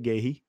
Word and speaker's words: Guehi: Mark Guehi, right? That's how you say it, Guehi.